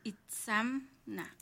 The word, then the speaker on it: Itzamna: Doña Itzamna. No, Itzamna, porque es M-N-A.